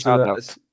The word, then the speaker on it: shoutout: Shootout. Wie sagen wir das? – Shoutout.